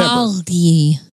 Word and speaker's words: Magli: It's M-A-G-L-I, or is that a silent G in Italian? Mali. – I don't remember. Mali is how you actually kind of say.